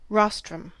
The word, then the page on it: rostrum: American English